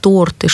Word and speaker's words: торты: И, кстати, слова, которые почему-то сложно запоминаются выпускниками, слова «банты», «лифты», «торты», «шорты», они ведь все в одном ряду. Ударение падает на первый слог.